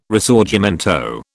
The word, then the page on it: Risorgimento: British English